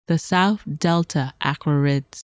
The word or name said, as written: South Delta Aquarids